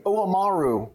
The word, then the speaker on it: Oamaru: So 18 year old Dunedin double cask, and then you guys are based in Oamaru.